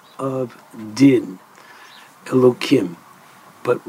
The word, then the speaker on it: Elohim: Now, it's pronounced Elohim.